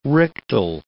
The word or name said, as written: rictal